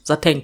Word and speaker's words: Satin: In der deutschen Sprache wird Satin auch mit Sateng ausgesprochen. Das ist die richtige Aussprache davon. Sateng.